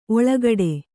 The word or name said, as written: ಓಲೆಗೊಡೆ